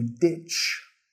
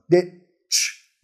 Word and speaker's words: ditch: I'm going to ditch work, ditch school, etc. De la pronunciación, nota, al final tenemos ch, ch. No es un sh, sh. Primero tenemos que bloquear el aire con la lengua. Ditch, ditch.